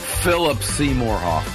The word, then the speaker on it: Philip Seymour Hoffman: Philip Seymour Hoffman.